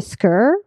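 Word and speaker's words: skör: But if you put an umlaut, the two little dots over the O, yeah, I know what you're talking about. – It changes pronunciation to skr, which rhymes with fur, like instead of score.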